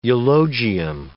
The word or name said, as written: eulogium